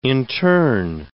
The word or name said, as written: interne